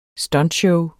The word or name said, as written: stuntshow